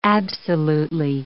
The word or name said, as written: absolutely